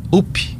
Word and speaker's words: UP: A Samara do UP, é assim que pronuncia esse partido? – Isso.